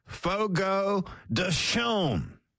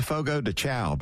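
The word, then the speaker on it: Fogo de Chão: Get this from Fogo de Chaume. I always said Fogo de Chow, but I guess I've been saying it wrong all these years.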